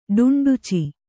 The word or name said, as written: ಡುಂಡುಚಿ